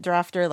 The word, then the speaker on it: Drafter: So first of all, thank you to Drafter for letting me know that is in fact the correct pronunciation, Drafter like laughter.